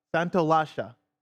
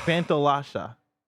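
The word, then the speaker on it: Santaolalla: Santolasha. – I don't think that's right. – So the two L's make a S-H sound?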